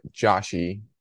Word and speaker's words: Josh: Yeah, well, some people call it Joshy, like my wife sometimes.